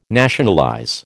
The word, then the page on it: nationalize: American English